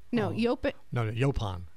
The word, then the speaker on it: yaupon: But Paul actually recommended using, I'm going to butcher it. Yaupon holly? – Yaupon. Absolutely. – Did I say it correctly? – Yaupon. Yaupon holly.